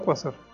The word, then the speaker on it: quasar: ¿Quasar o Quasar? – No tengo la menor idea, yo lo digo y como salga. Sin embargo, aquí checando mi guía de pronunciación, resulta que lleva acento en la A, Quasar.